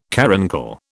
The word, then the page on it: caruncle: British English